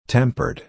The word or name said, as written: tempered